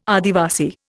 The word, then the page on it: Adivasi: Indian